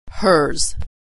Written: hers

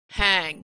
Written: hang

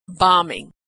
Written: bombing

